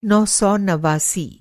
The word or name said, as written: नौ सौ नवासी